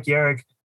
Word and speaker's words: Yarick: Yes, my name is Mike Yarick.